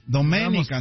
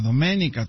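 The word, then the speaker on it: Doménica: Domenica, Antonio, Domenica, con acento en la E, Antonio. – Ah, sí, Domenica. – Trae acento, sí, cierto.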